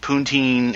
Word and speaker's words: poutine: So gravy and beer and poutine, and I said it right, so we can still keep this a good clean program.